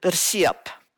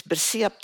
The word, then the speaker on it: bersiap: But okay, during this, after the war, during this, we called it bersiap.